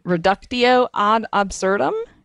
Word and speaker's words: reductio ad absurdum: And that's one of those, I'm probably going to mispronounce this, Wendy, reductio ad absurdum. Did I say yes. – That correctly? – I think that's the way Marx says it.